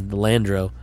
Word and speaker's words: Delandro: I said it wrong the first couple times. Delandro, D-E-Landro, L-A-N-D-R-O.